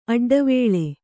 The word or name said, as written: ಅಡ್ಡವೇಳೆ